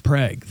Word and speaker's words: Prague: Yeah, because, like, you know, Prague here in Nebraska, it's pronounced Prague. That's how they spelled it. – Right. – But there'll be a lot of people that don't know that, so they'll go, it's Prague.